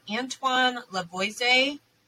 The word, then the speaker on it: Antoine Lavoisier: I don't think she was engaged to someone else before him, but Antoine Lavoisier, that's how you say his name.